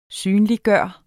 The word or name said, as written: synliggøre